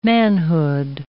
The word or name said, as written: manhood